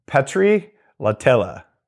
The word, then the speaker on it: Petteri Lahtela: His name is, here we go, I'm going to say this right, Petri Latella. Petri, how'd I do? – Yes, very well.